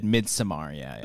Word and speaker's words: Midsommar: Is that how you say it? Midsommar? – Yeah, I think it's the director has said Midsommar, The director himself has said it.